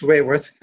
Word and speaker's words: Raworth: I think it's Raworth. – Yes.